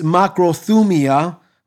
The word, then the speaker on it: makrothumia: Now, in the Greek, the word patient is makrothumia. Makrothumia, it's spelled M-A-K-R-O-T-H-U-M-I-A. Again, M-A-K-R-O-T-H-U-M-I-A.